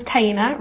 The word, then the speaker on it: Taina: Yeah, my name is Taina, rhymes with hyena, if that helps you remember it.